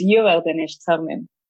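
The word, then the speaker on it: Über den Sternen: So Imperium's Uber Den Sternen. I can't say it. I'm sorry, guys, if I'm killing it. I'm sorry. – Yeah, I think it's Uber Den Sternen. That's like the correct pronunciation.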